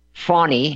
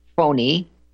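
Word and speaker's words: Fani: Why do you think that Fawny, she likes to call it Fawny, I like to pronounce it Phony,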